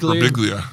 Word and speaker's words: Birbiglia: Birbiglia? – Birbiglia.